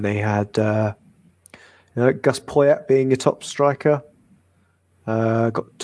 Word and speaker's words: Poyet: Did I hear you say his name in Uruguayan? Poyet. – Oh, is it Poyet? I don't know. – Yes, Gustavo Poyet.